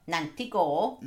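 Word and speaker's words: Nantgarw: Showcase Cinema in Nantgore, can't pronounce that. – Nantigore.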